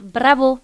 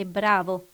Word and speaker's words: bravo: Par exemple, vous dites « bravo », alors que c'est « bravo ». – Voilà. – Vous mettez l'accent toujours sur la dernière syllabe, alors que pour nous, c'est l'avant-dernière.